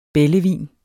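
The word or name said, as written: bællevin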